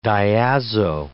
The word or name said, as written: diazo